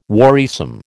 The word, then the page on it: worrisome: American English